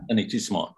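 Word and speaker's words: anéantissement: Did I pronounce that correctly? Come on, help me out here a little bit. – It's anétissement, right. Very good, thank you.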